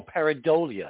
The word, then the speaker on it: pareidolia: See, now the critics are going to say, "Oh, you guys are totally whack jobs, because there's nothing but pareidolia." Is that how you pronounce it? Oh, pareidolia, I think. – Pareidolia. – Thank you.